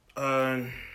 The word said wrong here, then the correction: Joaquin: how you say his name? – Can you spell? – Joaquin. – How you pass high school? I don't know. They just giving out diplomas. It's like, look, man. The J is silent.